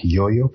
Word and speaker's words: Yo-Yo: algunas personas pronuncian Crazy Like You, pero lo cierto es Crazy Like Yo-Yo, porque Yo-Yo es es un instrumento que nosotros cuando éramos niños brincábamos, tenía una cuerda.